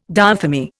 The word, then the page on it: diathermy: Indian